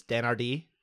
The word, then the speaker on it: Dan Hardy: His name is pronounced, I was asking GSP about this, Dan Hardy. – Oh, my bad. – Dan Hardy.